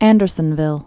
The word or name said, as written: Andersonville